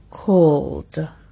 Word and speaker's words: called: Por ejemplo, si cuando la forma de presente termina en sonido vocálico o en sonido consonántico sonoro, como por ejemplo call, llamarse, al añadir el ed se pronuncia called, called, d, d.